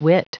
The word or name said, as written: whit